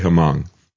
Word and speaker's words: Hmong: These are people from Laos, the Hmong group, if I'm saying it correctly. I don't know if I'm pronouncing that right. It's H-M-O-N-G. I'll say Hmong.